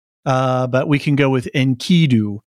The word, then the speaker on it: Enkidu: However, my understanding of the pronunciation of the name of Gilgamesh's friend, as I've always heard it pronounce it, is as Enkidu, with the emphasis on the second syllable.